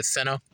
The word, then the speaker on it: Seno: Seno? – Yes, it's right.